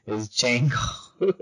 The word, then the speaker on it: Django: Means Django, not the Django, because my boyfriend corrected me on that, because I have not seen the movie. Yeah, he told me the D is silent, so I had to stop saying that. It's Django.